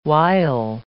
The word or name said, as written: wile